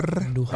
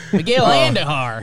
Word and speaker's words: Andujar: And first of all, make sure you roll your R's when you say Andujar. – Andujar. – Miguel Andujar.